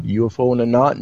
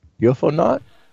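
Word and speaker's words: UFOnaut: UFO in the Knot. No. It's UFO Knot. – UFO Knot. – Like astronaut, UFO Knot.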